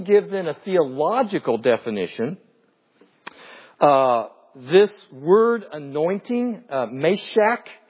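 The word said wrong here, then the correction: mashach: or no, I'm sorry, I mispronounced that, moshach.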